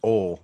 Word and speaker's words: oil: So if y'all didn't catch that, Rob said the proper way, he pronounced oil the proper way. There's no way a three-letter word has more than one syllable.